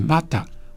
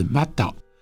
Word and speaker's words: mata: 麻豆是来自于西拉雅族，它的发音是 mata 或者是 mata，原来意思指的是眼睛，引申成为中心点枢纽的意思。